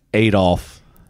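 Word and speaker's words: Adolph: My middle name is Adolf.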